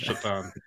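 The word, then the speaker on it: Šatan: Well, his name is spelled S-A-T-A-N. He's Slovak. But that always seems to be a running joke that his name was pronounced Satan, but it's Shatan.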